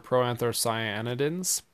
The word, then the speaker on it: proanthocyanidins: Look at your proanthocyanidins.